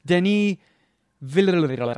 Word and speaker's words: Denis Villeneuve: Denis Villeneuve. I think it's officially pronounced Denis.